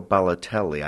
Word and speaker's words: Balotelli: They said they resembled, and this is a little bit odd, Mario Balotelli.